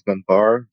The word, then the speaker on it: Ganbar: Yeah, so LinkedIn, just my name, Yoav Ganbar, G-A-N-B-A-R, and Twitter, GitHub, all those.